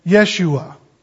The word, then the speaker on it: Yeshua: Now, Joshua, that's an Americanized way of pronouncing his name. The Hebrew would have been Yeshua.